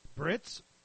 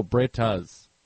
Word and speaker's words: Brittas: The Britas Empire. B-R-I-T-T-A-S. Britas. Right? – Brits? – No, Britas. B-R-I-T-T-A-S. – Like Tatas? – Yeah, like Tatas.